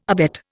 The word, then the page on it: abet: Indian